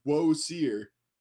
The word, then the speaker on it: Osiris: We call him Osiris now, but apparently the pronunciation correctly back in ancient Egypt, according to some historians, is Woe-seer, which is really fucking weird.